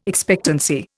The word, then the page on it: expectancy: Indian